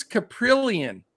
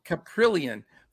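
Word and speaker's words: Kaprielian: It's Taylor Hearn, the lefty, 7K against the guy that I can never say his name, James Caprillion. Did I say it? Right. – Yeah, you nailed it. – All right. Caprillion, 5.8K for the A's.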